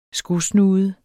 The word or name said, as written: skosnude